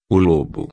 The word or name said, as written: lobo